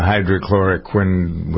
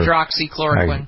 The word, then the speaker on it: hydroxychloroquine: It's a $20 dosage fee for the combination of the Z-Pack and the hydrochloroquine, whatever. – Hydroxychloroquine.